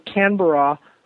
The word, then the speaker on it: Canberra: I stood a good chunk of the time in Sydney, and then I went to, I'm probably saying it wrong, Canberra. – Canberra.